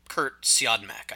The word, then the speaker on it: Siodmak: and I'm sorry, we mispronounced it. It's Kurt Siodmak, I think.